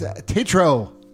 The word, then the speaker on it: Tetreault: Denis Tetreault.